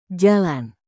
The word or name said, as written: jalan